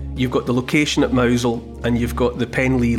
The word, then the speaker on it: Mousehole: That's spelt, if you're not familiar with it, it's as in mouse hole, M-O-U-S-E-H-O-L-E, but it's pronounced Mousel, you know, like M-O-W-Z-E-L in that kind of Cornish way. Mouse hole becomes Mousel.